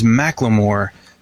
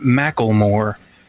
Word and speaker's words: Macklemore: His name is Macklemore, not Macklemore. It's not like tackle box.